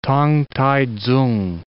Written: T'ang T'ai Tsung

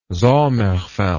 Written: sommerfeld

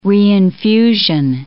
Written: reinfusion

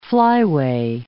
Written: flyway

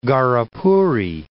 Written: Gharapuri

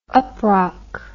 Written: uprock

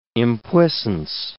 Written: impuissance

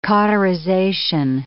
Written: cauterization

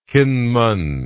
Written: Kinmen

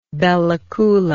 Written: bella coola

